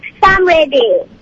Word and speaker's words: Samriddhi: Okay, my name is Samridhi, and I think the answer is 53 stripes and stars. – And what's your name again? – Samridhi. – How do you spell it? Samridhi. – S-A-M-R-I-D-D-H-I.